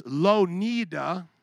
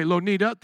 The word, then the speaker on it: Louw-Nida: It's Louw-Nida. Lon- Louw-Nida, is that how you would say it? Okay, Louw-Nida, thank you.